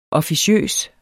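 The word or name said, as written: OFFICIØSE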